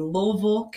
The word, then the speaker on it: Louisville: Hey, I'm live in Louisville, Kentucky. Yes, Louisville, Kentucky, L-O-U-I-S-V-I-L-L-E. We do not pronounce it the way it's spelled